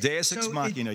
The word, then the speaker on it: Deus Ex Machina: I'm an English major and political science, but I think there's a literary character, and it's called, the Deus Ex Machina? Am I mispronouncing that? – Deus Ex Machina, yeah.